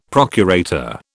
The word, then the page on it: procurator: British English